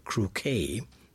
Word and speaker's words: croquet: Those who are familiar with the game of croquet, now watch out with that word, it's spelled C-R-O-Q-U-E-T, but pronounced croquet, along the lines of bouquet, etc.